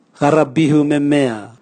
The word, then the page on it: garabihu memea: Raga